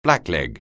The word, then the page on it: blackleg: anglais